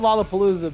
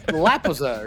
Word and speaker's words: Lollapalooza: It's called Lollapalooza, but we've known that since 1993. – Lapita dapita dapita. – Yeah, but Eric pronounces it like an Indian tribe, like Lapaza or something.